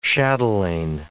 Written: chatelaine

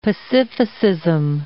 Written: pacificism

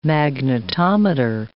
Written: magnetometer

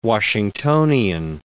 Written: Washingtonian

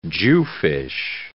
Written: jewfish

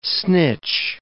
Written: snitch

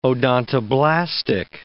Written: odontoblastic

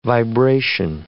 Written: vibration

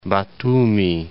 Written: Batumi

